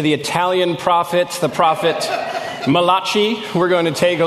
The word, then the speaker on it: Malachi: The pronunciation we usually use for this is Malachi.